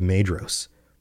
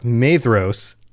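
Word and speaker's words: Maedhros: And in the confusion that they wrought, they came near to the standard of Madros. And I just realized, I've been doing it again. Pronunciation is rough. Madros, it's a DH. I talked about this on the last episode, and yet I forgot. Madros, by the standard of Madros.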